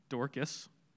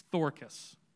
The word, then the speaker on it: Dorcas: It's not Dorcas, it's Thorcas, as the Greeks would pronounce that.